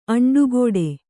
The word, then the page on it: ಅಂಟುಕೊಡು: aṇṭukoḍu